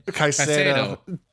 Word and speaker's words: Caicedo: Is that how you say his name? – Caicedo.